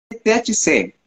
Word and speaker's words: THC: la verdad es THC, ¿Por qué? significa, porque THC es terapéutica, humanizada, consciente. Ah.